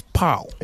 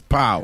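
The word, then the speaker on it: pão: And the Portuguese pronunciation or word for bread or translation for bread is pau. – It's pau.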